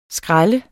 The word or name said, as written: skræl